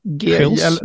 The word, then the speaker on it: Gils: Det, det finns en liten lirare som heter Marcel van, hur man uttalar det, Gils? – Gils. Ja.